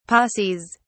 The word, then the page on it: posses: British English